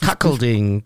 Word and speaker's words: Cuckolding: Cuckolding. Ja, ja. Spricht man das so aus? Ich habe ganz gefragt. Cuckolding, hat er gesagt.